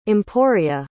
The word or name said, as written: emporia